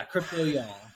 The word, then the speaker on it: Crypto Y'all: Yeah, and did I pronounce it right, Crypto Y'all? – Is that? Yeah, when you're not from the South, it doesn't roll off the tongue, but yeah, Crypto Y'all.